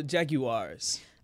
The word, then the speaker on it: Jaguar: Next up, Jacksonville Jaguars.